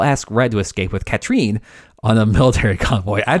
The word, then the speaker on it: Katrine: While working out how to steal Duvall's one-of-a-kind black pearl, Red begins an affair with Duvall's much younger wife, Katrine. I think it's listed as Catherine, but everyone pronounced it Katrine. – Catherine because that's how the character name is spelled in the credits.